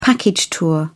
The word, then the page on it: package tour: British English